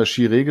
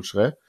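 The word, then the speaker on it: Kyereh: Genau, also das war ja dann ähm, Genau, Kofi, ja, also ich hatte immer Chiré gesagt bei uns, aber er heißt ja wohl jetzt, äh, also richtig ausgesprochen ist ja wohl Schrä, genau.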